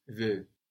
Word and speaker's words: veux: Tu veux.